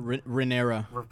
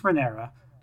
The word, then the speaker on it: Rhaenyra: Princess Rhaer, I can never say her name, for whatever reason. – Rhaenyra. – Princess Rhaenyra. She's often feels like she's being sidelined in her own story.